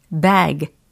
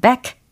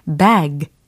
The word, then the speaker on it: bag: B, A, G이니까 bag. 이렇게 발음을 하셔야지. back. 이렇게 하시면 등 혹은 뒤라는 단어를 발음하시게 되는 거죠. bag. 끝소리에 더 유의를 해 주셔야 되겠습니다.